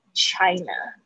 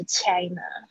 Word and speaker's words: China: 然后这边呢，我需要大家注意一下 ch 发音哦，这个不要把自己的这个国家念错了。这个 ch ch China 不是 China，不是 China，不要再继续掐了啊！China 它是 ch ch 的嘴巴稍微微微嘟起来，把这个 ch 的声音 China 好吗？